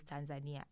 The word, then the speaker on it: Tanzania: And usually when people ask me, like, is it Tanzania or Tanzania? I'm like, oh, whatever. But now, no, I will not be saying whatever. It is Tanzania. Say it right. Say it freaking right.